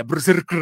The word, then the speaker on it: BRZRKR: Y si tú ves y vas a una tienda especial o buscas en línea, es B-R-Z-R-K-R, pero se pronuncia Berserker, nada más que sin vocales.